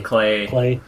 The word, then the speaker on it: Klee: Klee. Rhymes with Clay. Play. That's how you remember.